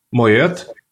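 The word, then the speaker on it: Moët: А, собственно, по-моему, Моет. По-моему, Моет, да. Вот, Моет. Тоже как раз-таки это голландская фамилия, поэтому Т читается.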